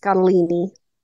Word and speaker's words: Scottoline: It looks like Scottoline, but it's pronounced Scottolini.